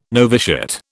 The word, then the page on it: noviciate: British English